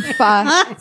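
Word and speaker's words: pho: I got to say, as a result of people mispronouncing the word fa, there are so many great restaurant names for fa places. Like fucking. – Yeah. – But people think it's fo, so they say foking.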